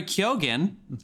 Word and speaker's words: Keoghan: And Barry Keoghan, that is how you say that name, who was in Killing of a Sacred Deer with Colin Farrell.